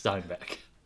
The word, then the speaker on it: Steinbeck: Can't say his name now. – Steinbeck. – Steinbeck, everybody. It's Steinbeck.